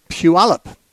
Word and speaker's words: Puyallup: Let's talk to Jacob from Puyallup. I think I said that right. Jacob, welcome. – Yes, that's correct.